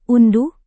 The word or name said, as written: unduh